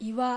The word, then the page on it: Iwa: Japanese